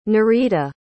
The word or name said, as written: Norita